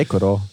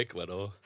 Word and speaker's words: Ecuador: det er Ecuador. – det er Ecuador.